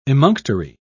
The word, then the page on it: emunctory: British English